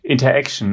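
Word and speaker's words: Interxion: Eins hast du schon genannt, Interaction, die Interaction, ne? Spricht man die aus.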